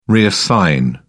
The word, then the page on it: reassign: British English